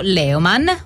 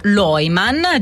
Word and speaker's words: Leumann: E torniamo in un luogo di cui vi abbiamo parlato e che in realtà abbiamo anche scoperto, riscoperto recentemente, ovvero sia al villaggio Leumann o Leumann, dipende dalla pronuncia, se alla italiana o alla tedesca.